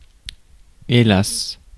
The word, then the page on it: hélas: [e.las]